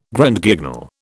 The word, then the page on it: Grand Guignol: British English